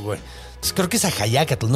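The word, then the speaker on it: Axayácatl: Es que no, creo que sí dice Ajayacatl. Yo siempre le aviso Axayacatl, pero... – En la escuela la maestra me lo pronunciaba Axayacatl. – Pero es que, sí, según tengo entendido, es Ajayacatl.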